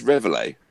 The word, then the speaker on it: Reveille: I believe the appropriate pronunciation is Reveille. – It is.